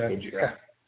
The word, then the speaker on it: Gojira: I always wondered if you pronounced that with like the hard J or like the Gojira. – Because then it sounds like a Spanish girl's name. – It's French. Well, I mean the band's French, so I'm gonna go with Gojira.